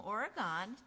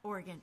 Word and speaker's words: Oregon: Facebook, YouTube, MVTV, our local cable access television station here, or Capital Community Media in Salem, Oregon, TV station. – Oregon, yeah. – Yep, you can, Oregon. Oh, I said it wrong.